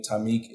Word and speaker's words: Tymiak: T-H-E-T 2.0. And I'm on Facebook as well, which is my name, Tamik. It's Tamik Amir Hawkins, T-Y-M-I-A-K.